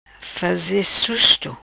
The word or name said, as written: faze susto